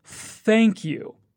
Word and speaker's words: thank you: Now, pay close attention to the pronunciation of the words thank you. When you say the TH in thank, stick your tongue out and use your voice. Thank you.